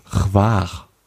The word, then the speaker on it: Hvar: Chwar. Chwar. Also wird geschrieben H-V-A-R. Also Danke heißt Chwala, Chwala, das wird so ähnlich ausgesprochen, Chwar.